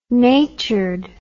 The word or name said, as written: natured